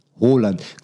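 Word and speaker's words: Haaland: הולנד, איך מבטאים את זה? – מה? – זה הולנד, ככה מבטאים את זה? את השם של הבחור, של הילד. – אומרים, כן, הרלינג בראוט הולנד. הולנד. כמו אודגור. אודגור כותבים עם A, הרי, אבל אודגרד נכתב. אבל אומרים אודגור, אז יש את הקטע הזה בשפות, אתה יודע, פה ב...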